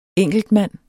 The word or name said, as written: ENKELTMAND